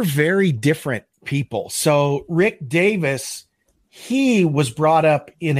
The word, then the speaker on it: Davies: It's spelled like Davies, but I think he actually pronounces it Davis.